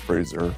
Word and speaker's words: Fraser: While discussing his new movie, The Whale, Brendan taught Adam Sandler the correct way to say his name. – When I first met Brendan Fraser, who we're talking, that's your full name, right, Brendan Fraser? – It's Fraser.